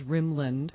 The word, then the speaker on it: Rimland: Dr. Bernard Rimlin, which is spelled R-I-M-L-A-N, like it sounds, Rimland.